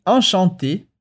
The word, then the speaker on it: enchanté: E aqui é importante que enchanté termina em E accent aigu, o E com acento agudo. Portanto, não tem diferença na pronúncia entre masculino e feminino.